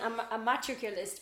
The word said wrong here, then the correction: amatorculist: but it's amatorculist.